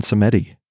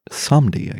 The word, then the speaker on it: Samedi: It taught me how to pronounce Samdi, because my whole life, I thought it was Baron Samedi. That's how I would say it. But it's Samdi, I guess.